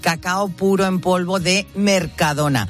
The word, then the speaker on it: Mercadona: ¿Cómo se pronuncia Mercadona? – Mercadona.